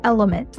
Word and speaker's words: LMNT: This podcast is brought to you by Element, that's spelled L-M-N-T, which is a company that I have the chance to invest in and have loved since day one.